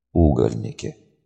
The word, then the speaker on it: угольники: «Угольники, надо же!» Постановка ударения на первый слог придавала слову необычное и яркое звучание.